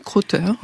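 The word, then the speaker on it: Crowther: Je veux un chien, de la Belge Kitty Krauter, je ne sais pas si je prononce bien. – Oui, Krauter. Oui. – Krauter, donc pas du tout bien, chez Pastel.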